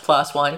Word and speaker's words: PLOS One: And I think it's pronounced PLOS One, P-L-O-S One. I've always thought it was PLOS One.